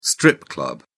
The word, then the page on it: strip club: British English